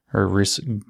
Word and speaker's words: Grundrisse: And then in another piece, or another work by Marx, Gundrisse, Grundrisse, I can't pronounce things in German, G-R-U-N-D-R-I-S-S-E, SSE, Grundrisse.